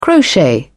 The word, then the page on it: crochet: British English